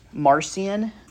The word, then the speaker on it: Marcion: So where we left off last week was talking about Marcion. I believe that's the pronunciation of his name. I heard one online call him Martian, which I think the way he believed, he definitely was not from Earth.